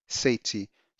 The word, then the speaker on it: Saytee: Once you're there, you can type in the titles and search, or you just type my name, Larry Sati. So my first name is spelled L-A-R-R-Y. My second name is spelled S-A-Y-T-E-E.